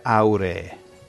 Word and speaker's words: auree: Insomma, è molto, Cioè, si dice auree. – Okay. – Cambia l'accento.